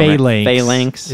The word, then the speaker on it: phalanx: and they actually formed a phalanx. – Yeah. – Phalanx is, that's probably wrong. – Phalanx is how I've heard it pronounced.